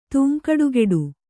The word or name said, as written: ತೂಂಕಡುಗೆಡು